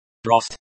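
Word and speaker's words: Brost (брось): Brosti. Mya chair. Brost. Myash. Breakdown. Brosti. Imperative verb, second person plural, you all, of beroesiteiri, to throw. Pronunciation. Brost, the stress is on the first syllable, bro.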